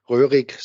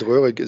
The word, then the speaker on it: Roehrig: Most of the people have a difficulty, especially in connection with my last name, Roehrig. So Harald Roehrig is pretty much too much R's.